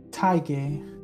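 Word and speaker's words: Tadhg: It's pronounced Tiger. Like tiger, but without the R.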